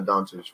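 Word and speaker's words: Dončić: Yeah, I don't know what happened to Doncic.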